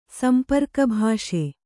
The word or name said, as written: ಸಂಪರ್ಕಭಾಷೆ